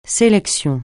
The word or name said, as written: sélection